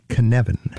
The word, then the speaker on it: Cynefin: Tell us about Cynefin.